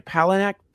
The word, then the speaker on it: Palahniuk: Speaking of Fight Club, let's follow that lineage, which is Chuck Palahniuk. That's how you say his name, right? Palahniuk. That's the author of Fight Palahniuk.